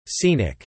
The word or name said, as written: Scenic